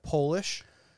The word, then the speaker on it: Polish: Also, did you know that Polish, if you were, so you pronounce it Polish, in English, you pronounce Polish, and it's spelled with a capital P. – Polish. – Right. If you make it a lowercase p, it becomes Polish.